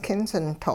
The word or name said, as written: kinsinto'